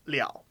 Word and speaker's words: 了: And that's probably the most common word you'd use with 不了, but really when you see verb bu or de followed by that character, that's when it's pronounced liao.